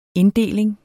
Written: INDDELINGER

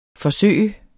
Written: forsøge

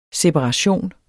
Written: SEPARATIONENS

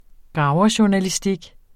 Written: graverjournalistik

